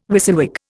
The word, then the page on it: WYSIWYG: Indian